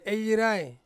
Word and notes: yirai: Ansus

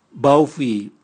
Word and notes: bwauvwi: Raga

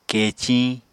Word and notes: keciñ: Apatani

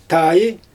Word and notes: taaj: Xyzyl

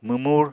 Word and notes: mwmur: Apatani

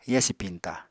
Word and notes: yasi piñta: apatani

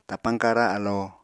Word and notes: tapañ-kara alo: Apatani

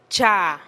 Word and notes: cha: TdVZ

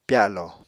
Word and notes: pyalo: Apatani

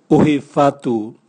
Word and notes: uhi vatu: Raga

